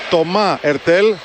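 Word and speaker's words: Thomas Heurtel: Τομά Ερτέλ, χωρίς το σίγμα, το προφέρει ο Γάλλος.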